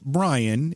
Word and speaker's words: Brein: By the way, as I say Bryan all night, you need to know his last name is spelled B R E I N.